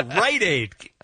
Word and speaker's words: Rite Aid: No, Rite Aid. Emphasis on the right.